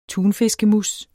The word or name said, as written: tunfiskemousse